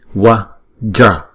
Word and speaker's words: vajra: Chữ vajra này là kim cương, là hai âm. Không? Wow. Chữ V này nè. Thì chúng ta đọc là như chữ ua đó. Ua đó. Wa, wa, wa. Không? Wa. Rồi chữ J này chúng ta đọc là j, j. R. Không? What, wa, ja, wa, ja.